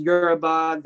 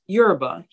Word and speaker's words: Yoruba: Where he's talking about something about Nigerian culture and he mispronounces Yoruba. He says Yoruba. And I was baffled, absolutely taken aback because my thing is if you're a historian, teach correctly. Like you have, with history, you're given facts. – Yep. – Like there's dates, there's events, there's people involved. Like, and you've done this so many times, you should be able to do it in your sleep. Pronounce it correctly. Who taught you how to say Yoruba?